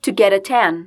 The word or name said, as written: to get a tan